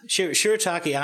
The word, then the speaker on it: shirataki: But it was just like a normal dinner, and I had some, I have no idea how to say this word, shirataki, is that how you say it? – Yeah, that's right.